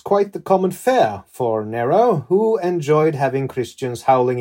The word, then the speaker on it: Nero: I just say Nero, kind of using the standard English pronunciation. Nero is more or less correct. I don't worry too much about that.